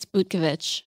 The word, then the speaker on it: Butkovic: It's Butkovich.